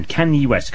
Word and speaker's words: Kanye: I've just worked out how to pronounce his name. Kanye West, coming up after this.